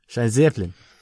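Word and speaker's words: Schienenzeppelin: I can pronounce it. – Schienzeppelin. – There we go. Schienzeppelin.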